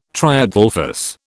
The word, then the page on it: triadelphous: British English